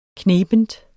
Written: knebent

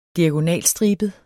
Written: diagonalstribet

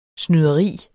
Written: snyderi

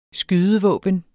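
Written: SKYDEVÅBNET

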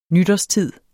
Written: nytårstid

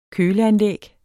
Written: køleanlæg

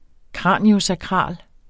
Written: kraniosakral

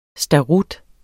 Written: starut

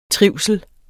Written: trivsel

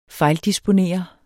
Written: fejldisponere

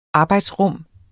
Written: arbejdsrum

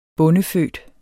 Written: bondefødt

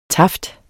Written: taft

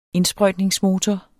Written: indsprøjtningsmotor

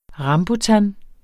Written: RAMBUTAN

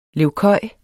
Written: levkøj

